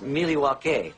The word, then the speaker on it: Milwaukee: actually is pronounced Milwaukee, which is Algonquin for the good land.